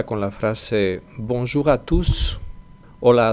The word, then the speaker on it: tous: Todos, tous, es una excepción porque hemos visto que la S final normalmente no se pronuncia, pero en este caso sí, sí, es una excepción.